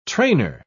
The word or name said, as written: trainer